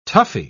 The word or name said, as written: toughie